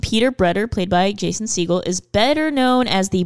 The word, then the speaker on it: Segel: I say Segel. – I say Segel as well. – Like the bird.